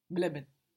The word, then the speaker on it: bleiben: I-E in German gives us E, like a long E, like in D, the word for the, spelled D-I-E. And E-I gives us I, as in bleiben, which is B-L-E-I-B-E-N.